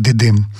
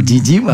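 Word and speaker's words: Didim: К нам приехал заместитель мэра города Дидим. – Тут у тебя произношение такое турецкое «Дидим», правильно? Я сказал «Дидим», а вот «Дидим», наверное, был да.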